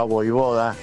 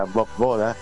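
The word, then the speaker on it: Vojvoda: Lo veo a Voivoda, que así se debe pronunciar, y no como la mayoría lo pronuncia Bob Boda.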